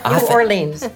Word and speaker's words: New Orleans: And I learned how to pronounce New Orleans. Not New Orleans.